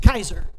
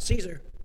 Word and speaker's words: Caesar: And then at the very top of the pyramid was Caesar, which actually was pronounced Kaiser. That's a hard C, not a soft C. For us, it comes out Caesar. For them, it was Kaiser.